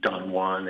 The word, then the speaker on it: Don Juan: It's called Don Juan, and although Don Juan is a kind of a way that we have of referring to a highly sexed man or a Casanova, Byron called him Don Juan because there's a kind of an in joke where British people like to mispronounce phrases in foreign languages.